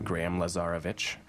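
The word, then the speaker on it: Graham Lazarovich: Graham Lazarevich, or you're gonna have to spell that one out each letter.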